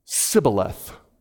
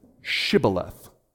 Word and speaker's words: Shibboleth: They say Sibboleth, and he says Shibboleth.